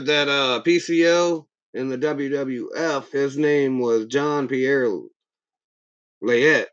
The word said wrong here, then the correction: Jean-Pierre Lafitte: Jean-Pierre Lafitte.